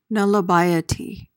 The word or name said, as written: nullibiety